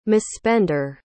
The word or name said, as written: misspender